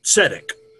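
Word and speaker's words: tzedek (צדק): The three letters here are, we'll call it a Z, although it's pronounced like a TS, D and Q. So we have the word tzedek, which means rightness.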